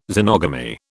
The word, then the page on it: xenogamy: British English